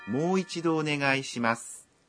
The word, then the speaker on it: もう一度お願いします: 모의 발음은 모의 모음 오를 한 박자 길게 발음합니다. 그럼 발음을 연습해 볼까요? – もう一度お願いします.